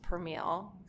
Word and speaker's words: Permele: I'm just Permele, because I have this wild name. – And spell that for everybody. – P-E-R-M-E-L-E.